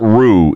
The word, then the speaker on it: Rue: Let me get Rue in Nampa.